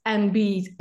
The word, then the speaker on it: enby: It's NB as in N and then a B, you know, non-binary. But a lot of non-binary people refer to themselves as NBs, and then it's spelled ENBY.